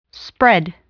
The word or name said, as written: spread